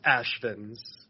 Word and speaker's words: Asvins: Pretend it's A-S-H. It would be pronounced Asvins.